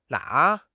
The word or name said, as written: na3